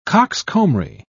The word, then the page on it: coxcombry: British English